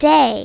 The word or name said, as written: day